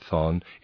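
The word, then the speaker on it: Chthon: Readers aside, Thon, I'm pronouncing it Thon, it's C-H-T-H-O-N.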